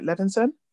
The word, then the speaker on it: Levinson: I also felt like, I've read a bit about Sam, I don't want to butcher his surname, is it Levinson? – That's right.